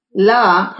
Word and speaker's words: La: La, pronounced L-A like the city, Harrell Styling Company was birthed 10 years ago.